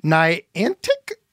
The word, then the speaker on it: Niantic: Let's go to Asa in Niantic, Illinois.